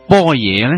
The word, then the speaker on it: 般若: 波野波罗蜜多六个字，如果用广州话直接读呢几个字，就是般若波罗蜜多。但是为什么般若要读成波野呢？